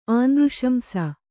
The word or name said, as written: ಆನೃಶಂಸ